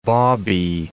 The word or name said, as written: bawbee